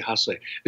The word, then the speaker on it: Hase: Sting beats Hiroshi Haas, or I'm just butchering all these Japanese names. Sorry. – Hiroshi Hase.